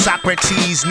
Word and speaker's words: Socrates: I am on the run and it's pronounced Socrates.